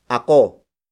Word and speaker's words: ako: The first pronoun is ako. Ako. Ako. Ako is spelled as A-K-O.